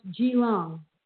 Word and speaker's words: Geelong: Oh, Geelong, pronounced Geelong. – Yes.